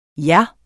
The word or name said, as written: ja